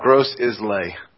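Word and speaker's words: Grosse Ile: There's something called Gross Isle or Gross Ill. Do you know how to pronounce this place where we're going? – It's called Gross Islay. – Gross Islay, that's it. That's Gross Islay.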